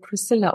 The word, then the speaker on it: Priscilla: Ich muss nur gleich zu Anfang sagen, mein Name wird Priscilla ausgesprochen, aber Priscilla.